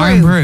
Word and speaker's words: Irn-Bru: Is it Iron Brew? – Iron Brew, yeah. – I-R-N-B-R-U.